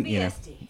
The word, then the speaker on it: Embe Esti: So we were going to talk about basically Embe Esti, or is it, it's ST, right? Am I pronouncing that right? – Embe Esti. – Embe Esti. See, I've been pronouncing it right this whole time.